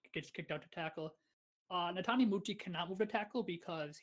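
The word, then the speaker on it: Netane Muti: Natane Muti? – Oh, yes. Yes, yes, yes. – Natane Muti.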